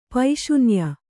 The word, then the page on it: ಪೈಶೂನ್ಯ: paiśunya